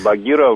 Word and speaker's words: Багира: Багира, несомненно, мужское имя, и Багира в этой... – Багир и Багирах — это мужское имя, да. Но H не читается, да, поэтому Багира.